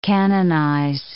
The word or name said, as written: canonize